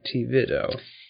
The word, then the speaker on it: Tevido: Tevido. The name, just a little history, comes from the combination of two Spanish words, the word for tissue, which is tejido with a J, and the word for life, vida.